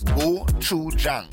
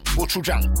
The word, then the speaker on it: Gochujang: So it's three syllables. Gochujang. Gochujang.